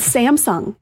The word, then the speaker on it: Samsung: First on the list, though, is Samsung. And I can't figure out, like, how are people saying Samsung wrong? – Samsung. – Samsung. Okay. So Samsung. The wrong emphasis on the wrong syllable.